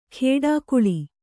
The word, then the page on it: ಖೇಡಾಕುಳಿ: khēḍākuḷi